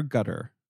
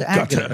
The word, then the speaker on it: Agutter: I mean, Jenny, is it Agutter or Gutter? – I've gotten slammed for saying it wrong, and now I'm in my head about it. – I always worry that Gutter sounds kind of pejorative. And I do. I think it's Agutter.